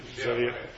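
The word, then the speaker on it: Zodhiates: Zodiates, is that how you pronounce it? – Zodiates.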